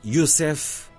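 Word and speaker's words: Yosef: E é um certo que nos dá o momento central no monumental romance Ainda Ontem, de Samuel Youssef Agnon, com pronúncia hebraica,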